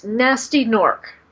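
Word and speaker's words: Gnasty Gnorc: G-N-A-S-T-Y. – Just sounds nasty, yeah. – Yes, Nasty Nork. – Okay. – The G is silent in both of those.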